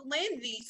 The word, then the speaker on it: Lindsay: Now, I may not be spelled like mine, you know, we get a little fancy when we get to the spelling, but it's still pronounced Lindsay.